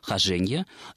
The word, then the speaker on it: хожение: то там это слово зарегистрировано только с ударением на втором слоге, хожение, и, следовательно, нет никаких оснований называть эту книгу «Хожение за три моря».